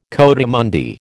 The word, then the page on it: coatimundi: American English